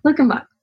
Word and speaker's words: Luckenbach: And let me, if I'm saying it right, is it Luckenbach, Texas? Yeah, Luckenbach. Like the Waylon Jennings song.